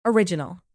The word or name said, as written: original